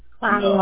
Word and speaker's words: Clio: Linden Road in Silo, right? S-L-I-O, Michigan? – Clio. – No, Clio. It's C-L-I-O. – C-L-I-O 48420, correct? I mispronounced that.